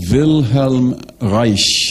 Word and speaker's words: Wilhelm Reich: And then going forward, you come to Wilhelm Reich. It's hard for me not to pronounce a German name in a German way. But it's Wilhelm, W-I-L-H-E-L-M. Reich is R-E-I-C-H.